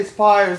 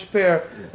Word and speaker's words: Speyer: S-P-E-Y-E-R. – Thank you. – No problem. That's German. So sometimes I say Speer, sometimes Speer. You can do whatever you want, basically. Yeah.